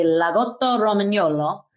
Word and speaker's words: Lagotto Romagnolo: it is called a Lagotto Romagnolo.